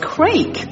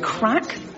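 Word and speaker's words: craic: You know, and actually a lot of people think it's pronounced crake because of the spelling. But you ask any Irish person and they'll tell you it's actually crack.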